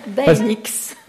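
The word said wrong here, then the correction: Daeninckx: En fait, ça se dit Didier Deninx. – Voilà, Deninx. Je le prononce toutes les lettres.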